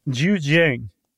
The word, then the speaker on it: Zhejiang: Zhejiang.